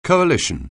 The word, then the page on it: coalition: anglais